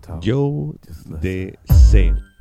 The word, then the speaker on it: Jodeci: Joe de C. Y te digo, así que se pronuncia porque lo sé. Porque además lo pone en este disco. Joe de C.